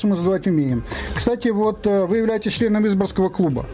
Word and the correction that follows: Изборского: Чуть-чуть неправильное ударение. Изборского. Город Изборск.